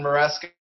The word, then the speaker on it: Maresca: So you go look for Glenn Maresca on Instagram, and I'm on there.